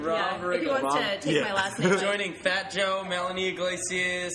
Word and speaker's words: Riggle: Riggle? – Yeah.